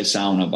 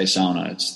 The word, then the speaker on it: sauna: We say sauna, by the way. I'm going to say sauna. That's the correct way to say it. – Okay. – Sauna would be the usual sauna. Way that Aussie say it.